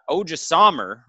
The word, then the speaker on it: Odrisamer: And Odrisamer, right? Did I say that right? Mm-hmm.